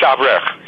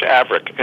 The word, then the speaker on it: Avrich: Yeah, it's Avrich. Yeah. – Avrich. – Yeah. – Beautiful. Okay. – Yeah, in English, Avrich. In Hollywood, I'm known as Robert Avrich.